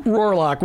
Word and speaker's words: Rohrlack: What a privilege it is to have Bob and Sue Rohrlack with us in the studio.